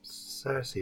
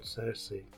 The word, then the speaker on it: Saoirse: Saoirse, isn't it? I think she's called Saoirse.